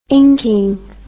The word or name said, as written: inking